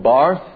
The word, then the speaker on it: Barth: Now, the father of neo-orthodoxy is a man called Karl Barth, or Barth, as he may have been pronounced in the German.